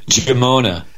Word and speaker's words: Giammona: Now, Colonel, I don't want to butcher your last name, so pronounce your last name for me. I may have got it wrong. How do you pronounce it? – Giammona. – Giammona. I got it right then.